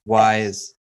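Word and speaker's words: Ys: It's like Y. – Wise. – Like, that's how it's spelled. Just Y-S.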